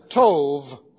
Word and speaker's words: tov: The Hebrew word for good here is tov, T-O-V, B pronounced like a V. Tov.